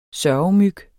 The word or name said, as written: sørgemyg